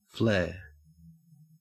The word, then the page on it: flare: Australian English